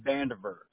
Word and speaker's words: Vandiver: Vandiver. – You want to spell that for me? Is it V-A-N? – V-A-N-D-I-V-E-R. It'll look like Van Diver.